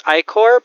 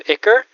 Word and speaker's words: ichor: Though it bleeds what the dictionary tells you to pronounce ichor, but I've decided I really am a fan of the less accepted, more modern pronunciation of ichor, because come on.